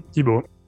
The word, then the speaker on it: Thibaut: Sí, sí, es así, Tibot.